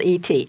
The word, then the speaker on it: Et: Now, the title of this next poem is Et, which is spelled E-T, Et. Dialectical past tense and past participle of eat.